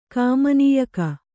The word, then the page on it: ಕಾಮನೀಯಕ: Kannada